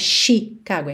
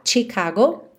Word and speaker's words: Chicago: Chicago, atenção, a pronúncia dessa palavra tem um tch, tch, não é Chicago, é ti, ti, ok? Tipo tchau, tchau, TCH, Chicago.